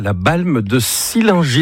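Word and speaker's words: La Balme-de-Sillingy: Et bon, ce début de semaine, on accueille Laurent avec ici, Pays de Savoie, la Balme de Sillingy. Est-ce que c'est comme ça qu'on prononce l'endroit où tu vis, Laurent ? – C'est parfait.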